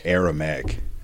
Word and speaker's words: Aramaic: It was written in Aramaic? I don't even know how to say it. – Aramaic.